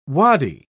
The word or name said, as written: wadi